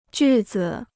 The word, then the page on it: 句子: jù zi